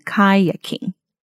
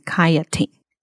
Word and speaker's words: kayaking: 然后我就重复回去听了，发现还真的，那个字是 kayak kayak，所以它如果是一个动名词的形式的话，应该是要念成 kayaking，但是我在节目上把它念成 kayaking。所以把 K 念成 T 的音，嗯、